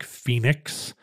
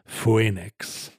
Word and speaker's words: Phoenix: And there are instances where we just separated O and E and left them both in, like Phoenix, but it's pronounced as an E. You don't say Phoenix.